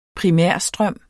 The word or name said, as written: primærstrøm